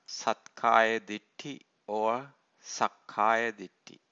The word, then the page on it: sathkaya ditthi: Pali